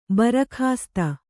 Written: ಭರಕಾಸ್ತು

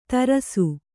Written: ತರಾಸು

